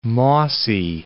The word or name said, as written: mossy